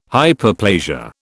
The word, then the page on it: Hyperplasia: British English